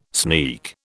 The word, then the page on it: sneak: British English